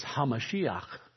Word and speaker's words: HaMashiach: How you say that in Hebrew is HaMashiach. And what that means, how we pronounce that, because we don't have guttural sounds in the English vocabulary, we pronounce it Messiah.